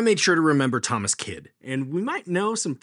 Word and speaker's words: Kyd: of Thomas Kidd today. And that's spelled K-Y-D.